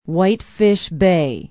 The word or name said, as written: whitefish bay